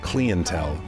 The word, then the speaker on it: Clientele: Alasdair MacLean, principal singer and songwriter for London's The Clientele, pronounced Clientele in British English, has fronted the band since their founding in 1991.